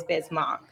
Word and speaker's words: Bismack: It always throws you, though, because it's spelled Mac, M-A-C-K. Yeah. So I feel like that's why people always get confused, because when they're looking at the name, they're going to pronounce it kind of like that. But it is Bismack.